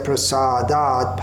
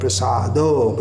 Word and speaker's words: Prasada: And it's not Prasado. In the first line of the eighth verse, we have Yasya Prasadat Bhagavat Prasado. But here it's Prasada. It changes the meaning if we mispronounce it. So Chaturvidha, Chaturvidha, Sri Bhagavat Prasada, not Prasado.